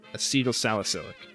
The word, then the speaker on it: acetylsalicylic: Acetylacetic, is that how you say it? I think that's... – Yeah, that sounds great. – Acetylsalicylic. Sorry. Acetylsalicylic acid.